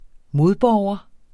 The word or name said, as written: modborger